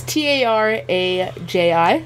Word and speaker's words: Taraji: but she it's T A R A J I Taraji.